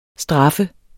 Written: STRAFFES

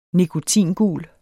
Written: nikotingul

